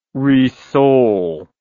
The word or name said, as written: rissole